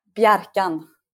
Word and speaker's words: Bjarkan: Bjarkan.